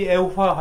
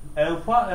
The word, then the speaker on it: Evfa: Bu Evfa kelimesi Efva ile karıştırılır bazen. Dile yakın olması yönüyle. Evfa, Efva ile. Bunlar ayrı ayrı şeyler. Osman Hazreti Osman'ın babası. O da Affan olarak bazen telaffuz edilir. Yanlışlıkla. Yani mahreçleri birdir. Affan. Affan değil. Affan başka. Evfa ayrı, Efva ayrı.